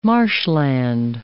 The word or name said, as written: marshland